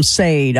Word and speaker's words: Sade: Sade, man. Yeah, exactly. Yeah, I said it wrong on live on radio. I called him Sade. I'm like, oh, I never lived that one down. So yeah, Sade.